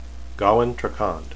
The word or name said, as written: gawyn